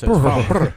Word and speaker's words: Farnborough: So it's Farnborough.